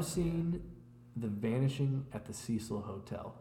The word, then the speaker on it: Cecil Hotel: The Cecil Hotel. – Never heard of it. – That's how you say it. – Yeah, the Cecil Hotel.